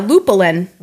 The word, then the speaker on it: Lupulin: So the beer that I got, again, is, how would you pronounce it? Lupulin? – Yeah, Lupulin.